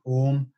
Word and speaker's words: Om: chanting using the the actual sacred chant of Om, which is actually three syllables, ah, oo, mm,